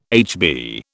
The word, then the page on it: HB: British English